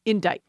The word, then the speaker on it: indict: Now, indict. I-N-D-I-C-T. This one is borrowed in from Middle French and goes back to the Old French endite, which is spelled E-N-D-I-T-E-R. You'll see there's no C in that.